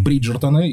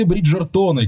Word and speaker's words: Бриджертоны: А, называется он «Бриджертоны», если произносить правильно совсем уж по-английски, с прононсом. Или «Бриджертоны», как, скорее всего, мы его будем называть в ближайшие минут 20-25, может быть, 30, вполне возможно.